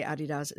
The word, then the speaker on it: Adidas: And also, the reason why I say Adidas is because that's how it's pronounced in the UK.